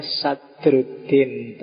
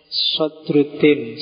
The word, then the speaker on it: Sadruddin: Ya kalau kalau pakai bahasa Indonesia bacanya Sadruddin, tapi maksudnya Sodr. Sodruddin. Sodruddin itu bukan namanya tapi gelarnya.